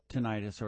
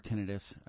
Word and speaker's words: tinnitus: There are cases where tinnitus or tinnitus, it's pronounced both ways, can be caused by earwax.